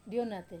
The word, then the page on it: onate: wamesa